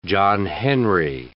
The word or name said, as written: John Henry